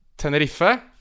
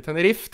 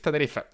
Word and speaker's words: Tenerife: Og, eh, kan du gjenta uttalen på det laget? – Tenerife. – Takk. – Ja, det er ikke Tenerife. Tenerife.